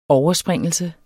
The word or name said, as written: overspringelse